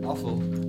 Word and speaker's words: Offal: This week on NZ On Air presents Long Player, we have Offal. Offal? How do you pronounce it? – Offal. – Offal. – Offal meat.